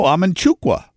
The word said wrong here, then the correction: Amanchukwu: Amanchukwu. – Amanchukwu, not Amanchukwa.